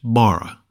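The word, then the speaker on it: bara (בָּרָא): The word for created is pronounced bara, and means made from nothing,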